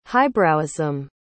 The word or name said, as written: highbrowism